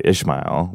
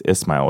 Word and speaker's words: Ismail: They would say Ishmael, which is a beautiful name, and it's really a Hebrew, not Arabic. So now when I hear that, I say it's Ismail with a soft S. It's Arabic, not Hebrew.